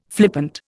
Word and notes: flippant: Indian

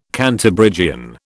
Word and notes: Cantabrigian: British English

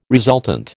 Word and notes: resultant: American English

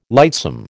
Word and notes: lightsome: American English

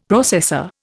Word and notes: processor: Indian

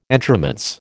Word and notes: entremets: American English

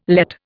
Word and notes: Lett: Indian

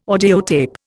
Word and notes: audiotape: Indian

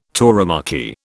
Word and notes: tauromachy: British English

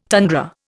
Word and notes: tundra: Indian

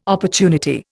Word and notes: opportunity: Indian

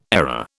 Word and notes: error: British English

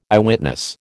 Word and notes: Eyewitness: American English

